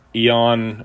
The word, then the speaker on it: Eion: Okay, since I started posting stuff on the dreaded Calvinism, I've gotten some fascinating responses here, and there's a fella named Eon, or it's spelled E-I-O-N, Tactics.